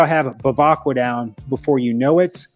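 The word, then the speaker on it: Bevacqua: But the name is Pete Bavacqua. I'll say that again, Pete Bavacqua. I noticed when I did it slower, I got the pronunciation down a lot better. Bavacqua. Again, Bavacqua.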